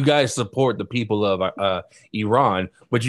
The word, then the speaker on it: Iran: has to beat Iran. My bad. Let me say it right so that, another reporter doesn't get mad at us. Iran.